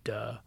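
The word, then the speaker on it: duh (德): and unfortunately in Mandarin it's pronounced "duh." – That's no hilarious. – Duh. It's called "duh."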